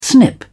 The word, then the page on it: snip: British English